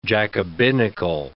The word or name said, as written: Jacobinical